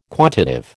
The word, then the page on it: quantitive: American English